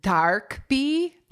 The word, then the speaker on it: DKB: But it's DKB is how it is just spelled. – Yeah. And it says on their wiki that it's pronounced Darkbi, and it's short for dark brown eyes.